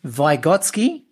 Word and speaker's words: Vygotsky: And then the person that you focused on was Lev Vygotsky. Is that how you pronounce it? – That's correct. Yep.